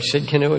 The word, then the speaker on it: Tsidkenu: And this morning we're dealing with Jehovah Tsidkenu, or Tsidkenu as some pronounce it, the Lord our righteousness.